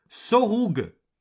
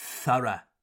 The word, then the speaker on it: thorough: En voyant ces mots, pour la première fois, en français dirait Sorug. Mais non, en anglais, ça se prononce Thara, avec un bruit de serpent sur le TH.